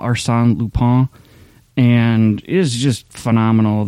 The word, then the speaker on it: Lupin: It's actually French, so they would pronounce it Lupin, but it's a French show, but there's English dubbed, you can read the subtitles, but it's a pretty good dub.